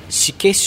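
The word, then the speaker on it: 四家: しけ酒造 4 つの家と書いてしけ」と読みます、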